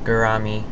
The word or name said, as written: gourami